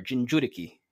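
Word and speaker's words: Jinchuriki: So in Naruto, all the nations have what's called a Jinjuriki, or Jinjuriki, if you're going to try to pronounce it Japanese.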